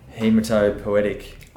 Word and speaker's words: hematopoietic: What they found was that, as you said, our stem cells, there's no way I'm going to say this correctly, hematopoietic.